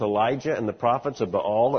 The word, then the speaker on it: Baal: The followers of Baal, often referred to by some preachers as Baal, it's actually Baal. You know, if you're going to talk about an idol, you ought to at least pronounce his name right.